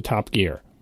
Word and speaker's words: Top Gear: T-O-P space G-E-A-R. And the British way they pronounce it, like which word has the emphasis? Like I'm driving down the highway and I shifted the car into top gear. That's how they say the show.